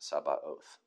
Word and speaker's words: Sabaoth: I looked that up. It's weird, but that's how you pronounce it, Sabaoth.